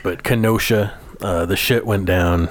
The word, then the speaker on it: Kenosha: Kenesha, Kenesha, Kenesha, Wisconsin. Is that how you say it? Kenosha. – Kenosha. Sorry, I don't.